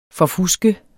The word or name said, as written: forfuske